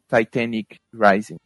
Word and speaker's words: Titanic Rising: que é o Titanic Rising, ou Titanic Rising, caso vocês queiram ser mais chatos com pronúncias, é, da Wise Blood.